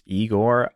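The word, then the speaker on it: Igorrr: Yes, this is by a band I believe is pronounced Igor. It's I-G-O-R-R-R.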